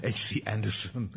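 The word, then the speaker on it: Andersen: Anderson, I keep on pronouncing him in the Danish way, H.C. Anderson wrote.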